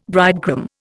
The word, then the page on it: bridegroom: Indian